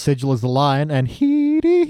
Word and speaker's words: Headey: But yeah, it is spelt like head, but it's pronounced he, not helped by the fact that, yeah, it's the same syllable sound, but it's spelt E-Y at the end, so it's Headey.